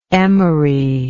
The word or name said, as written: Emory